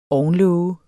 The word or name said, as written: ovnlåge